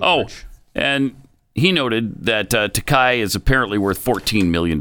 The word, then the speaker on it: Takei: And by the way, on your question about how to pronounce it, it is pronounced Takei, according to George himself.